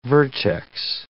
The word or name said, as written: vertex